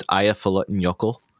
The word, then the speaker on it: Eyjafjallajökull: It's, if I remember correct, it's Eyjafjallajökull. – Eyjafjallajökull. – Something along those lines. – Eyjafjallajökull.